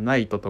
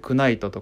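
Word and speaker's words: knight: どさいやあれなん無理じゃないストーンズっていうのなんだろうそのよくあるじゃんこういうの英単語とかでもあのナイトとか、ね、ナイフとかもうほんと初めてだったら絶対 K は発音して「クナイト」とか「クナイフ」って呼んじゃう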